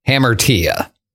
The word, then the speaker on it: hamartia: I can't pronounce it exactly, but the way I understand you're supposed to pronounce it is hamartia.